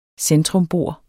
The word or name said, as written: centrumbor